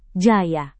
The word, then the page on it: jaya: Indonesian